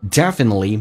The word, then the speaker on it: definitely: As long as it doesn't kill you, it will definitely make you stronger. It 的 T 去掉，Well 依然是这个发音，It 加个 All，这个单词有点难。 Definitely，首先那个 N 是发两遍，Not defi，那 defin，然后呢 N 在跟后面元音，definite，definite，同时 T 不发音，T 跟 L 组合，T 是不用发音的，气流只要阻塞一下，变成 Definitely make you stronger。